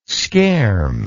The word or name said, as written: scherm